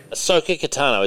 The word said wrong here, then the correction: Ahsoka Tano: Ahsoka Tano.